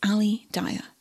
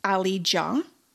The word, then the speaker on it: Aly Dia: This 31-year-old man was referred to as Ali Dia. In reality, he was actually called Ali Ja, fun fact. A-L-Y-D-I-A, but D-I-A is pronounced Ja.